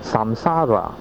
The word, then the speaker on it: samsara: 轮回在嗰个梵语的读音就是 samsara， samsara 的意思就是生死轮回。